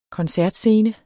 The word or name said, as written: koncertscene